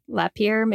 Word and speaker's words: Lapeer: Lapeer, Michigan. – That sounds right. – Does that sound right? – I mean, not the dead body part, but the pronunciation, right? – Thank you.